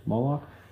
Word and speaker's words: Molech: It's like, so Moloch, I think it's Molech, Moloch. It's spelled M-O-L-E-C-H. Molech? I think you say it Moloch, though. Or pronounce it Moloch, whatever.